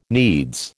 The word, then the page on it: needs: American English